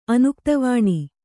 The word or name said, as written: ಅನುಕ್ತವಾಣಿ